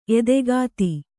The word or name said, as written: ಎದೆಗತ್ತಿ